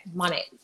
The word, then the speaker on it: Monet: And is it Monet? Is that how you pronounce it? – Yeah. – modern nature, right? Okay, Monet.